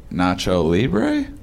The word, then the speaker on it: Nacho Libre: Nacho Libre? – Yeah.